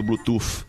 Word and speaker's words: Bluetooth: Opa, falei errado, Bluetooth. Não é Bluetooth com F de farinha, é Bluetooth.